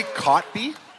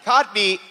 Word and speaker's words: Kotb: Did she say Cotby? – Cotby. And by the way, that's probably how you say it, but it's not how it reads.